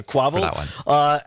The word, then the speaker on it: Quavo: And a rapper, is it Quavo? Is that how you say it? – I don't know. – Yeah, Quavo.